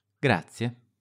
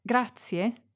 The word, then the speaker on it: grazie: It's also very common to use grazie, thank you. – Un caffè, grazie. – In fact, grazie, thank you, is probably the most useful word of all when being polite.